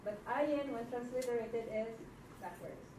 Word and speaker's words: Ayin: Ayin, another silent letter like aleph. Shh, it's very quiet. It will only say something when you put a vowel pointing.